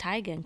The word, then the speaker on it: Teigen: No, actually, her real name's Teigen. It's pronounced Teigen, Chrissy Teigen.